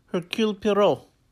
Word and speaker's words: Hercule Poirot: We saw the first installment of this Hercule Poirot series.